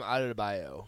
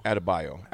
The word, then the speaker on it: Adebayo: How do you pronounce Bam's last name? Adebayo? – Bam Adebayo. – Adebayo. Adebayo. I had a bio.